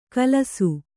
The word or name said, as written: ಖುಲಾಸು